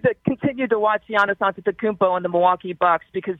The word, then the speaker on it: Antetokounmpo: And then you can pronounce it the way I just did, Antetokounmpo, where there's more T's and P's. That's more of the Greek version.